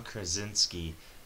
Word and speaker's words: Krasinski: I don't have any cast, but I really want John Krasinski. I can't say his name for some reason. – No, I think reasons. – You got it right. – Yeah, John Krasinski as Mr. Fantastic.